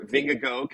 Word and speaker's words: Vingegaard: Okay, how do you pronounce his last name? Because I'm watching. – Vingegaard. – I am not saying it like that. So people are saying Vingegaard or Vingego. Can I say Vingego? Is that close enough? – Vingego is the closest I hear from Americans.